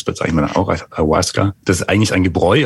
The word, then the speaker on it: Ayahuasca: Was ist das Ayahuasca und habe ich es richtig ausgesprochen? – Ja, hast richtig ausgesprochen, Ayahuasca.